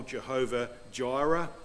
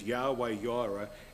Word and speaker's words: Yahweh Jireh: See, in the King James Bible, Jehovah Jireh. Well, the correct pronunciation of that is Yahweh Jireh.